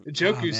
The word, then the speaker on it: Njoku: Njoku's still on the board too for you. – I love Njoku. – I think the N is silent.